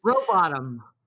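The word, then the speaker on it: Rowbotham: Robotom. I can't even pronounce it. I always want to say Robotham, but anyways. – Robotom. Like, you know, you hope you don't end up in the bottom of the lake.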